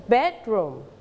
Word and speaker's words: bedroom: Okay, you have to stretch the first syllable. Bedroom.